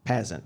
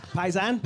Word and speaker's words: Pasin: This is a Chicago company founded in 1917 by an Italian immigrant, Antonio Pazin. Pazin. P-A-S-I-N. How would you say that? Yeah, of course you would. You Irish people. Pazin?